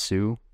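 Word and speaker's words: Hsu: And Stephanie, I think it's pronounced Sue, Everything Everywhere All at Once.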